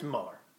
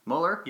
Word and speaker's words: Mueller: Mueller? How do you say it? I think it's Mueller. – Mueller? – Yeah.